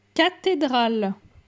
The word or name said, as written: Cathedrale